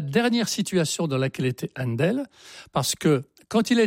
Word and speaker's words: Handel: Händel, H-A-N-D-E-L, ça se prononce tout naturellement Händel en anglais. – Ah bah oui. – Donc Händel. Ils n'ont besoin de rien, eux. Donc maintenant on l'écrit H-A-N-D-E-L, et alors, son prénom, qui était...